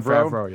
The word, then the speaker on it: Favreau: John Favreau, yeah.